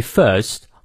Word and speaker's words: first: The sun himself is weak when he first 好，没错啊，这个 r 不念，因为在元音后。